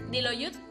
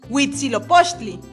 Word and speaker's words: Huitzilopochtli: ¿Diloyut? – Huitzilopochtli,